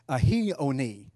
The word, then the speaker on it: aioni: That word there is aioni, almost A-I-O-N-I-O-S. Aioni is how it's pronounced.